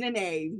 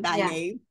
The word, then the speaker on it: Nynaeve: Her name's Nynaeve, not Nynaeve.